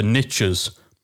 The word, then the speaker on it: niche: As the saying goes, the riches are in the niches. This is why I get why you US listeners prefer the pronunciation niche, because it rhymes with more things.